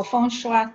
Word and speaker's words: Feng Shui: Eh, no solamente el Feng Shui o Feng Shua, como realmente se pronuncia,